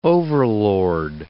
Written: overlord

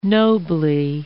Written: nobly

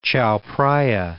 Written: Chao Phraya

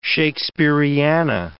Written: Shakespeariana

